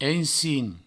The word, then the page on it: ensin: provençal